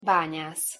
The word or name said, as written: banyasz